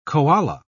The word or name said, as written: koala